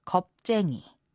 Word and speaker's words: 겁쟁이: Although it's not a nice thing to say, the Korean word for coward uses the word 겁 as well. It's pronounced 겁쟁이. 겁내지 마.